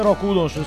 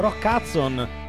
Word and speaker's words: Rock Hudson: mentre mio fratello diceva no mamma, non si dice Rock Hudson, si dice Rock Hudson.